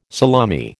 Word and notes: salami: American English

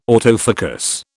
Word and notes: autofocus: British English

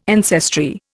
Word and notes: ancestry: Indian

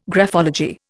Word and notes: graphology: Indian

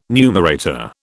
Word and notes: numerator: British English

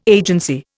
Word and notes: agency: Indian

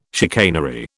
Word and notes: chicanery: British English

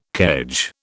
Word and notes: kedge: British English

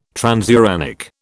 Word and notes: transuranic: British English

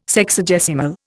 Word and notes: Sexagesimal: Indian

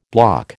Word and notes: bloc: American English

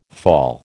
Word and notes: fall: American English

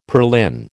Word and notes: purlin: American English

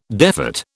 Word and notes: defat: British English

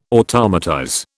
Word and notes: automatize: British English

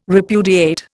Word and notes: repudiate: Indian

